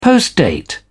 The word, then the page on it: postdate: British English